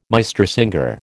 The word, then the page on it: Meistersinger: American English